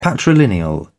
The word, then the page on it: patrilineal: British English